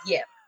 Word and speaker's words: Ypsilanti: Ypsilanti starts with a Y. Like, that city name starts with a Y, and it's Yip.